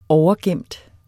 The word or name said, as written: overgemt